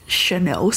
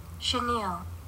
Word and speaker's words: chenille: Chenille.